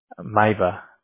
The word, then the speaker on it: Mayba: It's O-L-E-G? – Yes. – Okay. And then what's your last name? – M-A-Y-B-A. – M-A-Y-B-A. How do you say that? – Maiba. – Okay, I almost said maybe.